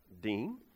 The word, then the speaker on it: diyn: D-I-Y-N, pronounced Dean.